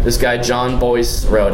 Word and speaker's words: Bois: John Boyce or John Blob? It's Bois.